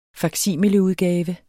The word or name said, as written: faksimileudgave